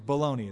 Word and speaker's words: bologna: That's bologna. Oh, excuse me, baloney it's pronounced.